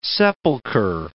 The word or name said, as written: sepulchre